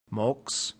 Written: mox

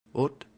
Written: ut

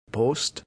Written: post

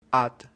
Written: at

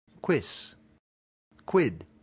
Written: quis

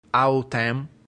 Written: autem

